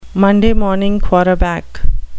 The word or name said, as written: monday morning quarterback